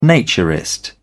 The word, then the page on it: naturist: British English